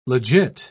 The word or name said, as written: legit